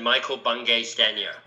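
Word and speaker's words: Michael Bungay Stanier: I would say that is not the most accurate pronunciation of my name that I've ever heard. Here's how I pronounce it. I pronounce it Michael Bungay Stanya.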